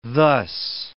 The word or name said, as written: thus